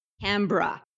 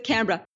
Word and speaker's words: Canberra: And I do have to say it's Canberra. I'm a Canadian. I had to learn all these mistakes myself when I arrived over in Australia 22 years ago. They got funny pronunciations for stuff. So it's Canberra.